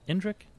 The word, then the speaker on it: Indrik: Indrik.